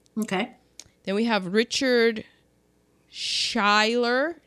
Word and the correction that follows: Schayer: No, there's no L in it. Richard Schayer. There we go.